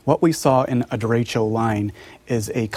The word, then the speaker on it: derecho: What is a derecho? And did I pronounce that correctly? – Derecho, correct, yes.